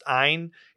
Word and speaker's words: Ayn: It's people say Anne, a lot of people say Anne, but she was from Russia, so it's pronounced Ayn.